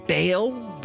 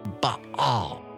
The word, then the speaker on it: Baal: Some people pronounce it Baal, but it's Baal.